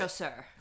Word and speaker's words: chasseur: Chasseur.